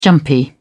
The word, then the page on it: jumpy: British English